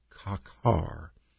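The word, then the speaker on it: kakar (חָקַר): It's pronounced kakar in the Hebrew, kakar. It's a Hebrew word.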